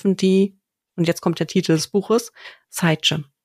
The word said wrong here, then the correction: Scythe: Ich hoffe, ich habe es richtig ausgesprochen. Englisches Wort für Sense. – Ach, Scythe.